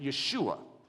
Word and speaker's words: Yeshua: However, his name is actually Joshua. You wouldn't pronounce it that way. You'd pronounce it Yeshua.